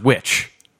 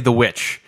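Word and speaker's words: The VVitch: And that's how they would have written witch, was with two V's, you know, but it's pronounced witch. Just say the witch.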